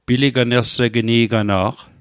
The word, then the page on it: piliganlsegnigana'q: Mi'gmaq